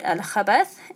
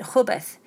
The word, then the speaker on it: al-khabath: Now, there's a mistake here that I've made in a previous episode with the pronunciation of that word, al-khabath, and I think I pronounced it khubath.